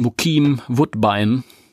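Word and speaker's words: Bokeem Woodbine: ich meine, er, er ist ja wahrscheinlich Amerikaner und dann wird sein Name englisch ausgesprochen. Richtig. Bukim Woodbine.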